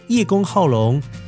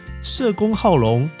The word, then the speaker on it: 叶公好龙: 所以呢，叶公好龙这一句成语的正确读音应该叫做社公好龙。这样子，乖乖有没有听清楚？是不是会有一点模糊？好了，之前呢，一开始跟大家讲叶公好龙，就是很担心大家会有这种模糊的感觉。社公好龙到底是在帮助人家那个社工吗？